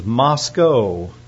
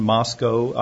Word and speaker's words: Moscow: So the correct pronunciation is Moscow, Idaho. So I guess it kind of rhymes at the end, Moscow, Idaho.